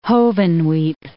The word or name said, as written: Hovenweep National Monument